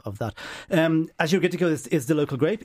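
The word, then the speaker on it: Agiorgitiko: So Gaia is the producer and it's Azure Gittico. I checked this on the internet today. – Okay, well done. – I've been saying, I've been giving it a hard G. It's a soft G, which means St. George, and it's from Nemea, which is arguably the best wine region in Greece.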